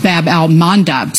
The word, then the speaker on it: Bab al-Mandab: Bab al-Mandab Strait. – You did pronounce it right.